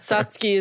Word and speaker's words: Satsuki: And I think Satsuki is the like correct pronunciation.